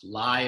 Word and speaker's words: Lai: His surname is pronounced Lai as in lie and not Lay as in lay.